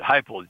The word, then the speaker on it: Heupel: Now I'm noticing I see Josh Heupel, I hope I'm pronouncing that correctly, Oklahoma quarterback. – Yeah, Heupel. Pronounced it Heupel, John.